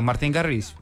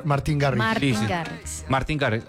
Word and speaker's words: Martin Garrix: Y entonces, ¿este cómo se pronuncia? Martin Garrix. Bien. – Martin Garrix.